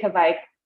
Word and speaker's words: kveik: I say Kveik like Viking is how I associate.